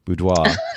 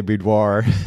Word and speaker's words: boudoir: By the way, I have to compliment you on using the French pronunciation boudoir versus what we normally say boudoir, which is, it reminds me.